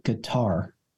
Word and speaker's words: Qatar: Because I always thought it was gutter. – Well, they said you could say guitar. Like you can use like a soft G in there, like Qatar.